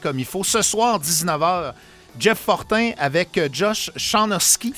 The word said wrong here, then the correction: Sinoski: Sinoski. – Ouais. Je l'ai toujours mal prononcé, ouais, mes excuses. Josh Sinoski, C'est peut-être un nom qui vous dit quelque chose, particulièrement quand je le prononce bien.